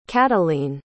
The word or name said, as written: catalin